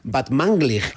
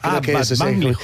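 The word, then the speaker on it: Batmanglij: Este es Rostam Van Banglig. – Muy bien. Ah. Casi bien pronunciado, ¿eh? O sea, tercero de Radio 3 tiene casi. – Van Banglig. – Batmanglig. – Batmanglig.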